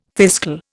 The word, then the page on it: fiscal: Indian